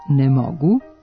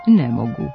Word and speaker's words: ne mogu: Kaže se "ne mogu", a ne "ne mogu". Do ove greške dolazi zbog analogije sa akcentovanim oblicima ostalih pet oblika sadašnjeg vremena glagola moći, u kojima je naglasak na negaciji. Tako se u prvom licu jednine, umjesto pravilnog oblika sa kratkouzlaznim akcentom na reči "mogu", pojavio oblik sa akcentom na "ne", pa se umjesto pravilnog "ne mogu" sve češće čuje nepravilan oblik "ne mogu".